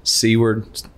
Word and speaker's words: Seward: Went off the coast of Seward.